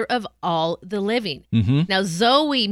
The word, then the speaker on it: Zoe: Zoe. – Zoe, yes. – Or it can also be pronounced Zoe. It is a Greek word.